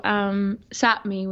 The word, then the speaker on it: Sápmi: So Sápmi, which is like our it's what we call our homeland. It's spelled S-A-P-M-I.